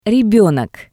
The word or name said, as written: Rebenok